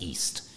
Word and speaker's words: EST: Well, you know, my next media platform is called Eastern Standard Time, which is spelled acronym-wise EST, which is also pronounced East.